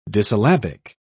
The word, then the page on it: dissyllabic: British English